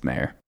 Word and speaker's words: Mayer: We're going with Matthew Meyer or Mayer. I don't know how to say it. – It's Mayer.